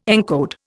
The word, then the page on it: encode: Indian